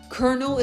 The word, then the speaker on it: colonel: Kernel is another one. I remember that one was a very confusing one for me because I'm like, it doesn't sound like how it's spelled. Kernel, C-O-L-U-O-N-E-L. Excuse me. Kernel, C-O-L-O-N-E-L. Another one was core, C-O-R-P-S, right?